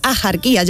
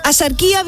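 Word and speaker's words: Axarquía: Asarquía viene del árabe al-sarquilla, que significa la oriental, y se pronunciaba con shi, ¿vale? Como lo mismo que México, ¿no?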